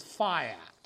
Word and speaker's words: fiat: Now, there are two pronunciations of that. Technically, the correct pronunciation is fiat, F-I-A-T, although you'll hear some call it fiat, which is actually the name of a brand of make of car.